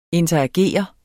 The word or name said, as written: interagere